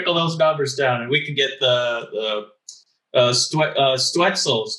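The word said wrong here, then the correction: Stützle: I know who's listening, but yeah, it's Stutzla. So Stutzla. You have to put the la at the end.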